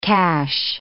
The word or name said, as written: cash